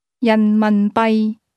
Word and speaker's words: 人民币: That's pronounced with two mid-falling tones, followed by a low-level tone. – 再讲一次。 – 人民币。